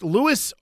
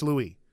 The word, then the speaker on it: Louis: Is it pronounced Lewis or is it a French pronunciation? I heard Venable say it as the French pronunciation. That's right. Whoever told you it was Lewis is wrong. It's Louis.